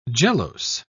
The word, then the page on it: gelose: British English